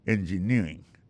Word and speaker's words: engineering: Electrical engineering. Engineering, ça, l'accent tombé le même sur avant dernière syllabe là.